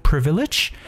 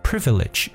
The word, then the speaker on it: privilege: 嗯，有特权的一层意思，因为 privilege 这个词本身呢就表示特权，所以大家记住这个词 privilege 这个词读的时候要特别注意一下它的读音 privilege 重音在前 privilege，那么 underprivileged 就是没有特权的，大家可以理解为弱势的。